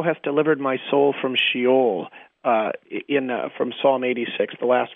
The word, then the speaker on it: Sheol: It's a Hebrew word. Sheol is how it's pronounced.